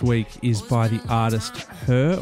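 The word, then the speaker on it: H.E.R.: Known professionally as Her, pronounced Her, an acronym for Having Everything Revealed.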